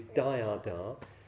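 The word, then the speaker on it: dāyāda: The word here is dhyada, D long A, Y long A, D A, which is receiving the sun's portion.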